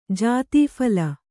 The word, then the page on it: ಜಾತೀಫಲ: jātī phala